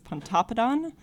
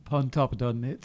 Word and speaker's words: Pontoppidan: So I think it's Pontoppidan locally. – Pontoppidan.